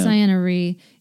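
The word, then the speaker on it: Ciena Ree: Yeah, well, I'm still making my way through Lost Stars, but I like the idea of, what is it, Sienna? I call her Sienna, but I don't think that's how you pronounce it. – It is Sienna Ree.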